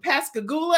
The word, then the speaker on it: Pascagoula: You graduated from Pascagoula.